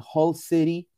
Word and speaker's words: Hull City: Hull City, así se pronuncia en inglés, Hull City, no FC, de la Championship de la liga inglesa.